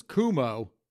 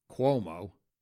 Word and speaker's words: Cuomo: I know that if your last name is Cuomo, and yes, folks, I do know it's actually pronounced Cuomo, but that's just what I like to call them.